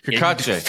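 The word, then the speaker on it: Cacace: Did I say it right? Kakache. – For the first time ever, yes, you said it right.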